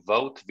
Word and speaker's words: Vogt: If I'm saying his name properly, is it Greg Vogt, V O G T? Is that his name? Yes, sir very impactful in your career.